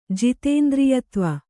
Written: ಜಿತೇಂದ್ರಿಯತ್ವ

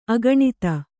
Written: ಅಗಣಿತ

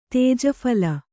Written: ತೇಜಫಲ

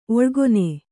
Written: ಒಳಗಾಣ್

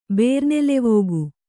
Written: ಬೇರ್ನೆಲೆವೋಗು

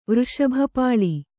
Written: ವೃಷಭಪಾಳಿ

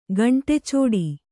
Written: ಗಂಟಿಚೌಡಿ

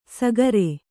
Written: ಸಾಗರೆ

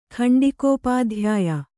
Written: ಖಂಡಿಕೋಪಾಧ್ಯಾಯ

